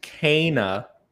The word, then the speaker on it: Kena: It's a I'm glad you liked Kena, Bridge of Spirits. That is the appropriate pronunciation.